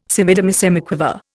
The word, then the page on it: semidemisemiquaver: Indian